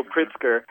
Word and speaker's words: Pritzker: Daniel Pritzker wrote and directed this film called Bolden.